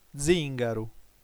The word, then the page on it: zìngaru: dzìn.ga.ru; ['dziŋɡaɾu]